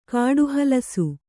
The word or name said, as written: ಕಾಡುಹಲಸು